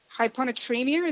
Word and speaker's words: hyponatremia: Hyponatremia, is that right? – Sounds good.